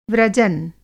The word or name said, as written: व्रजन